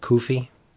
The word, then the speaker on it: Cuffe: It was written by Bob France and Kevin Koofy, C-U-F-F-E. Would you pronounce that Koofy? – I would say Koofy.